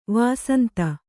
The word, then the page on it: ವಸಂತ: kannada